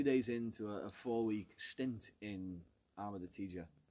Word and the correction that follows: Taggia: Taggia. – Taggia. Sorry, my pronunciation isn't great.